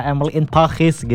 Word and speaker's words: Emily in Paris: Karena teman Pro 2, kalau kalian ngikutin Emily in Paris ya, Emily in Paris gitu harus bacanya ya.